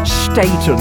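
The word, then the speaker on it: Staton: Yes, she pronounces her name Staten.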